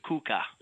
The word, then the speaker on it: Cuca: Is that their pronunciation? – C-U-C-A, Kuka.